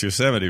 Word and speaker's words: Yosemite: Well, see, and I know it's Yosemite, but my friend's such a dingleberry.